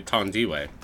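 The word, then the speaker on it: Thandiwe: I think it's Tondi. It's actually apparently Tondiwe.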